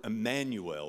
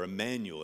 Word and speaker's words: Emmanuel: At Christmas, we trot out the word Emmanuel or Emmanuel. It's pronounced two different ways.